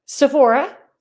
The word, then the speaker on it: Sephora: How do you pronounce it? – Sephora. Yes, you got it right.